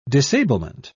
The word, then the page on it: disablement: British English